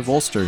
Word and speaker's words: Ulster: Alternative Ulster. Hopefully I'm saying that wrong. – Mm-hmm. Yeah. – Oh, I'm saying it right. – Ulster, yeah.